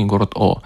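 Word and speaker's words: Å: А пишется он на латинице А с двумя точечками, ну, в произношении город О.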